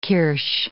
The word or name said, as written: kirsch